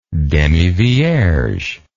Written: demivierge

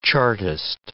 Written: Chartist

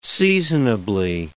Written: seasonably

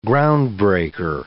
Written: groundbreaker

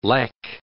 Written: lek

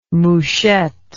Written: Mouchette

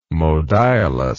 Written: modiolus

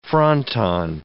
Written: fronton

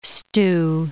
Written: stew

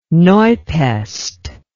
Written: Neupest

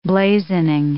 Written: blazoning

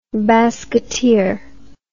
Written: basketeer

